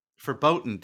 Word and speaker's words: verboten: or other tragic event is now verboten, basically. You cannot use it. – Verboten? – Verboten. Yeah, that's German. Sorry. That means forbidden.